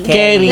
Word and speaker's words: Query: Pero ba, how do you pronounce query? – Carey. Like, Carey 1, 2, 3.